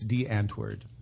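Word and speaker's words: Die Antwoord: It looks like die, but it's DeAntwoord. – How does it look like die if it's DeAntwoord? – D-I-E. It's a different language, Ross. – Wait, it's spelled D-I-E, but it's pronounced DeAntrade? DeAntra. – No, just the first word. Oh, my Lord. – Oh, I thought you meant the whole thing is spelled like that, because that's taking some liberties. – The first word is just D-I-E, and you pronounce it D.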